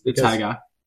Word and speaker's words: Haggai: How do you say that? We need to clear the air on the pronunciation. – Haggai? – I was wondering that. – Haggai? I thought it was Haggai. – Is it just Haggai? – It's Haggai.